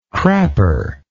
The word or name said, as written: crapper